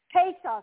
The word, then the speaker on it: Pesach: Well, last week, we began the study on Pesach. Passover. Pesach, it's spelled P-E-S-A-C-H. That's the Hebrew spelling for Passover, okay? Pesach.